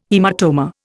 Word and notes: haematoma: Indian

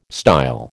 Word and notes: style: American English

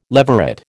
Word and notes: leveret: American English

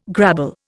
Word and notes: grabble: Indian

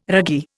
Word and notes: ragee: Indian

